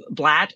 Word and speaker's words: Blatt: And I happen to love Blatt, which rhymes with your last name.